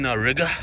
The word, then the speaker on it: Riga: So I'm out here, guys, in Riga, which rhymes with nigga.